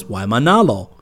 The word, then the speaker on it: Waimanalo: The town of Waimanalo is Waimanalo. A parade of ghosts is o-e-o, pronounced o-e-o, o-i-o.